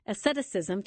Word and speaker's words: asceticism: Asceticism, tell me if I'm saying that right.